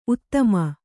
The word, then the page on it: ಉತ್ತಮ: uttama